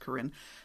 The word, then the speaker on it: Karen: I can recognize now Kachin pretty readily, and I can generally recognize Korean, which is spelled like the woman's name Karen, but it's pronounced Korean, because we have a lot of Korean people in our church.